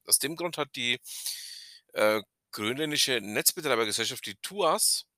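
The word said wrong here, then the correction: Tusass: TUSAS, so muss ich es richtig aussprechen, ähm, eigentlich dazu aufgefordert, weltweit das Thema Netz, äh, Netz also Festnetz, stillzulegen.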